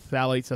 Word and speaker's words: phthalates: Once again, pesticides, flame retardants, PFAs and BPA, and also phthalates, is spelled with a PH, but phthalates, as it's pronounced, is found in plastics, toys, packaging.